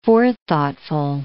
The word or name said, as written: forethoughtful